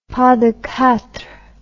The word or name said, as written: pas de quatre